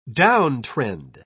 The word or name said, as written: downtrend